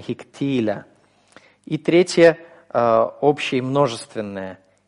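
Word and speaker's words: Хиктиля (הִקְטִילָה): Хиктальти, хиктальта, хиктальт, хиктиль, хиктиля. Вот особенно на это ударение. Третье лицо, женский род, единственное число. Хиктиля. Ударение у нас сохраняется здесь на втором слоге.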